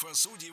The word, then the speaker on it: судей: Что интересно, раньше нормой было ударение на окончании «судей». Потом была в ходу двойная норма «судей» и «судей». А сейчас вариант «судей» вытеснил другой вариант и в гордом одиночестве присутствует в словарях. Поэтому только «судей».